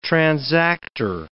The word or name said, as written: transactor